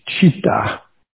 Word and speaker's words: citta: And some people spell it C-I-T-T-A, but it's like chitta, bodhicitta, like that.